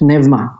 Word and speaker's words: pneuma: Likewise, in the New Testament, the Greeks had the word, in English we say it's pneuma, although my Greek friends would laugh at me. They say it's pneuma, I think, if you're going to say it right.